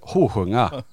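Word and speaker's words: Holsljunga: Eh, ifrån, eh, nu ska jag uttala det, jag vill säga bara för att jag är från Norrland, Holsjunga men det heter inte så, det heter vad fan sa han? ljunga. – Nej man säger med dialekt, hosjunga.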